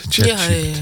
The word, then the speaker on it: ChatGPT: Nou, want als je zoals AI of ChatGPT. Zeg ik het goed? ChatGPT. Ja, ja.